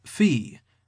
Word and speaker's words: Phoe: And her name, by the way, is pronounced Fee, but is spelled P-H-O-E.